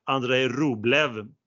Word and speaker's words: Andrei Rublev: Men det var en ATP 500 Rotterdam, Nederländerna, där vann ju ryssen Andrei Rublev med perfekt uttal.